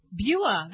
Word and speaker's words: Bua: Bua. – That's easy. It's like Buick.